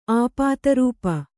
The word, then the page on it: ಆಪಾತರೂಪ: āpātarūpa